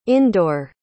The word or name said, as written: indore